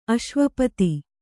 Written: ಅಶ್ವಪತಿ

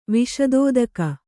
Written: ವಿಶದೋದಕ

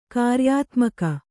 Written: ಕ್ರಿಯಾತ್ಮಕ

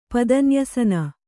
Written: ಪದನ್ಯಸನ

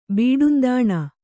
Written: ಬೀಡುಂದಾಣ

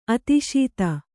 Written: ಆದೇಶತೆ

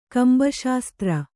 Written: ಕಂಬಶಾಸ್ತ್ರ

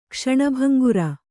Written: ಕ್ಷಣಭಂಗುರ

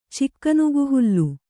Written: ಚಿಕ್ಕನುಗುಹುಲ್ಲು

